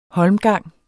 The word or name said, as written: holmgang